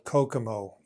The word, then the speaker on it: Kokomo: A while ago, Barry Boehm created a tool called Kokomo. Yeah, it's pronounced like the Beach Boys song, but it is unfortunately not a Caribbean island where you can go to get away from it all.